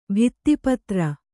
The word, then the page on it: ಭಿತ್ತಿಪತ್ರ: kannada